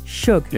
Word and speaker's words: Suge: Is it Suge Knight? – Shug. Yeah.